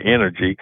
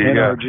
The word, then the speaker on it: NRG: Where people mispronounce it and say energy. – Energy. – But NRG. Yeah, but it was NRG, and they're going when the Houston Stadium signs come down, it's going to go back to Reliant Energy or Reliant Stadium.